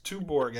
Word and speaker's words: Tuborg: Is it Tuborg? Listen, I say Uyer. – Yes, Tuborg, I think, is a fair pronunciation.